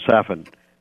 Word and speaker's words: Safin: and his name rhymes with Satan. His name is Safin.